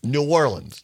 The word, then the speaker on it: New Orleans: New Orleans.